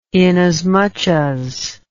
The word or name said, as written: inasmuch as